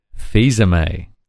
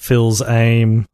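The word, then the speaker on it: Fils-Aimé: That Reggie, the head of Nintendo America, his surname is pronounced Fils-Aime. – Not Phil's Aim or... – Fils-Aime or anything. Yep. It's Fils-Aime.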